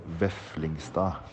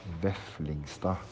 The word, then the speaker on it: Veflingstad: Øystein Veflingstad. Veflingstad. The D at the end is not pronounced, just the cost way we do things.